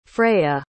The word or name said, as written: freyja